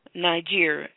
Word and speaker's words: Niger: So you say you're Nasir? – Niger. – Spell that? – N-I-G-E-R.